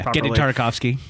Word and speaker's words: Genndy Tartakovsky: I can't ever pronounce the guy's last name. – Yeah, Genny Tartakovsky.